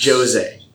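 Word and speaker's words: José: Get his name right. It's Jose.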